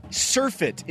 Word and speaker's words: surfeit: Yes, and we found, clarification, surfeit is the correct pronunciation.